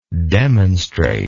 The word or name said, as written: demonstratedly